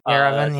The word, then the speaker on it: Yerevan: I want Yerevan. I hope I'm pronouncing that correctly, which lets your apostles choose any promotion. – Yerevan, yes.